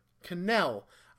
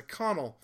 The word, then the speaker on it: Cannell: I found out that's how you pronounce their name. You may have heard me in previous times, and even in this audio clip, calling them Marty and Vanessa Connell. I apparently have been butchering their last name for a long time, and I'm fixing that right now.